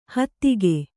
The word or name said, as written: ಹೆತ್ತೇಗ